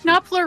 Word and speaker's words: Knopfler: London Suede, The Rag Picker's Dream by Mark... Knopfler? – Knopfler. You know Mark Knopfler.